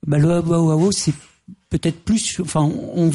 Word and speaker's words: Bauhaus: Le Bauhaus, je ne sais pas comment on le prononce, c'est Bauhaus, comme on dit. – Bauhaus, oui.